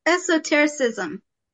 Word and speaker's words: esotericism: She is particularly interested in Mormon esotericism? Am I pronouncing that right? – Esotericism. – I missed a syllable.